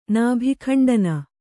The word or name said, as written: ನಾಭಿಖಂಡನ